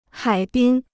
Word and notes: 海滨: hǎi bīn

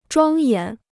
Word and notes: 庄严: zhuāng yán

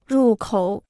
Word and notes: 入口: rù kǒu

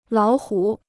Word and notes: 老虎: lǎo hǔ

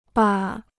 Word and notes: 吧: ba

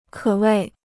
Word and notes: 可谓: kě wèi

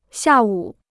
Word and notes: 下午: xià wǔ

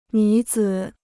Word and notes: 女子: nǚ zǐ

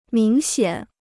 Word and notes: 明显: míng xiǎn

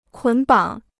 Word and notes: 捆绑: kǔn bǎng

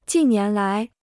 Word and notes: 近年来: jìn nián lái

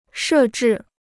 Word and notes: 设置: shè zhì

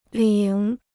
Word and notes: 领: lǐng

